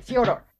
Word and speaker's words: Fyodor: I tried to say it correctly. I don't know if I... Fyodor? Yeah. Isn't that it? Fyodor? – Yeah. – Okay, Fyodor.